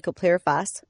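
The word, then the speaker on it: chlorpyrifos: How close was I on butchering that one? – Really close to butchering it. It's actually clopyrifos.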